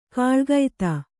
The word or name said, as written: ಕಾಲಗತಿ